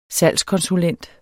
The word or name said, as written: salgskonsulent